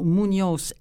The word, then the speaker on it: Muñoz: On va rappeler le titre du livre et son auteur, Luc Mercure, « Dans la grande nuit des temps » de Antonio Muñoz, M-U-N-O-Z, bon, avec un petit tilde là au milieu, mais peu importe, M-U-N-O-Z, Molina, comme ça se prononce, M-O-L-I-N-A.